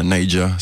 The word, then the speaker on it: Naija: Et moi, je viens du Nigeria, donc nous, les jeunes, quand on parle du Nigeria, on appelle ça Neja, c'est écrit Naija.